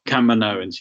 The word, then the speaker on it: Kaminoans: You had the, I always pronounce the name of their species wrong, the long tall necked Kaminoans.